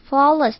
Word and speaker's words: flawless: 再来我们来看看 flawless 这个字， flawless 的 AW 要念成像是嘴巴含着一颗蛋的 O。